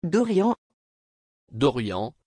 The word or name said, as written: Dorian